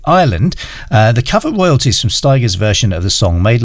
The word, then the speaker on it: Stigers: who always used to say Curtis Stigers rhymes with tigers.